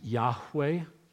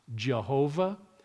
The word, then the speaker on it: Yahweh: God's name is Yahweh. It is not Jehovah. There is no J or V in the Hebrew alphabet.